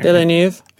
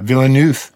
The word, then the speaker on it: Villeneuve: Villeneuve. – Yeah, Villeneuve.